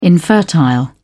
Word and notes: infertile: British English

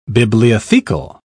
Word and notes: bibliothecal: British English

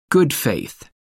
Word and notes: good faith: American English

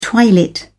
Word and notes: twilit: British English

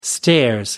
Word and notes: stairs: British English

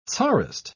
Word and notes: tsarist: British English

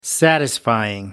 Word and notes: satisfying: American English

